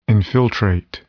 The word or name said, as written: infiltrate